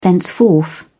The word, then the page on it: thenceforth: British English